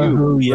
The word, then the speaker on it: Elihu: It's a fella named, I can hardly pronounce this name, Elihu, E-L-I-H-U, Elihu, right?